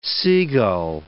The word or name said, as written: seagull